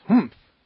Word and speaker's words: humph: If you're reading this story aloud, please pronounce humph exactly as it is spelt. For that is how he said it.